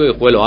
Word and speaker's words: Guijuelo: José Alberto García, vamos con el resto de las informaciones deportivas y en este caso, cantera del Club Deportivo Guijuelo.